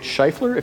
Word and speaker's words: Scheufler: The name is German, but I unfortunately am very American, so I say it Scheufler. If you want to try the German pronunciation, by all means, you'll probably get it better than I will.